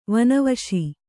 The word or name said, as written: ವನವಸಿ